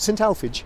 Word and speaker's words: St Alphege: Right, now we're turning right onto St Alphege. Is that how you pronounce it? St Alphege? – Yep.